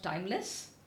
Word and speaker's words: Tymlez: And one is a company called Timeless. It's spelled as T-Y-M-L-E-Z.